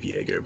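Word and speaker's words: Joerger: That the Kings Vlade Divac, not gonna really pronounce his name, is planning to fire the Kings coach Dave Jorger. – Vlade Divac and Dave Jaeger, but yeah. – Jaeger.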